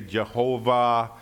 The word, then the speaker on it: Jehovah: In the Hebrew, so people debate how to pronounce it, Yahweh, some say Jehovah.